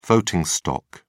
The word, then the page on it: voting stock: British English